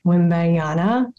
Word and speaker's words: Wembanyama: I was going to start off with Victor Wembanyama.